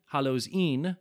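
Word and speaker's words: Hallows E'en: So we, of course, are familiar with Hallows Eve, or as the Scots dialect pronounces it, Een, Hallows Een.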